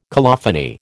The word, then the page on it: colophony: American English